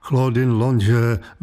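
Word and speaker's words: Claudine Longet: května Claudine Longe, vysloveno francouzsky, hlavně v 60.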